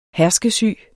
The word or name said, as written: herskesyge